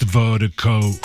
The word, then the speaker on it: Virdiko: And Vertigo, it's spelled V-I-R-D-I-K-O. That's V-I-R-D-I-K-O, pronounced Vertigo.